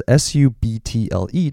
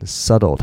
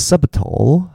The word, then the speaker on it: subtle: subtle 的拼法是 s u b t l e，但是我不知道你们发现，拜托有发现那个 b 并不发音，他念 subtle，他不是 subtle，他是 subtle。